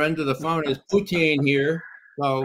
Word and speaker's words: Putin: The first is how do you pronounce Putin in Russian? Everybody assumes it's Putin, and that's why Ray McGovern says it that way. Is that correct, Ray? Well, so I say Lenin or Stalin, Putin.